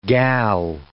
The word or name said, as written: gal